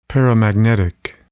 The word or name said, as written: paramagnetic